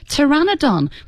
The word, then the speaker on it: Pteranodon: Pteranodon, which is surprisingly difficult because it's got a silent P at the beginning of it.